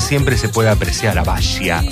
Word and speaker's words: Basia: Se pronuncia en realidad Basia, su nombre. Es polaca. Y este 30 de septiembre está celebrando 69 años. Se escribe Basia con S y se pronuncia Basia. Yo le digo Basia al castellano, pero es Basia.